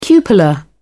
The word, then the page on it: cupola: British English